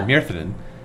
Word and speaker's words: Myrddin: and they were talking about how Merlin was originally called Myrthin, right? It was M-Y-R-D-D-I-N. – Yeah, yeah, that's Welsh, right? – It's Welsh, yeah. – So Myrthin.